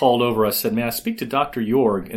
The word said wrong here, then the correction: Iorg: And immediately his assistant corrected me and said, it's Orge as in George. – I-O-R-G, right? – Yeah, but it's spelled I-O-R-G.